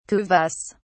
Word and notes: kuvasz: British English

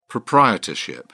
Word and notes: proprietorship: British English

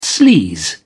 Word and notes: sleaze: British English

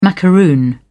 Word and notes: macaroon: British English

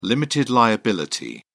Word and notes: limited liability: British English

